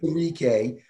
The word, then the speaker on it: Onwuzurike: Levi Onwuzurike. – That's about right. – Onwuzurike, yeah.